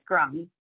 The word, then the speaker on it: Grammi: And I'll just note that my family says that the E in the letter of my last name may used to have had an accent aigu over it. So it's fine to do the French pronunciation as well. I'm totally fine with that. But so would you please pronounce your last name again one more time? – It's Grammi.